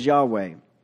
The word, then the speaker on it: Yahweh: which in the English we pronounce as Yahweh.